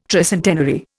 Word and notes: tricentenary: Indian

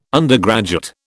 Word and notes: undergraduate: British English

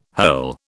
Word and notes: hurl: British English

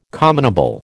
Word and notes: commonable: American English

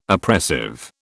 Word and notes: oppressive: British English

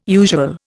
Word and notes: usual: Indian

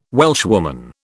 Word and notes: Welshwoman: British English

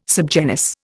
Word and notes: subgenus: Indian

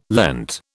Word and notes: Lent: British English

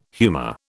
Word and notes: humor: British English

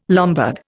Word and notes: Lombard: Indian

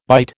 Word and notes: byte: American English

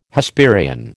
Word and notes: Hesperian: American English

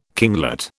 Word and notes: kinglet: British English

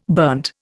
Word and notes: burnt: Indian

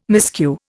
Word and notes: miscue: Indian

